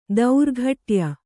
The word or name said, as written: ದೌರ್ಘಟ್ಯ